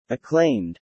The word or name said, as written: acclaimed